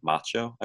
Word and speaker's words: MACHO: And another idea that came forward was something known as a MACHO, I believe it's pronounced, which stands for a massive compact halo object.